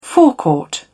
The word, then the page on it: forecourt: British English